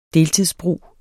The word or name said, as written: deltidsbrug